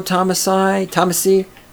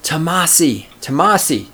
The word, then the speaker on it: Tomasi: Maybe you've never read anything by Rollo Tomasi. Why am I mispronouncing his name all of a sudden? Tomasi. God damn it. It took me 4 tries to get it right.